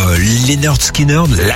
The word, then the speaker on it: Lynyrd Skynyrd: On va dire Lynyrd Skynyrd.